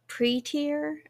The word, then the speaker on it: Pretear: I have just started reading the manga Pre-Tier, I think that's how you say it. Oh, yes.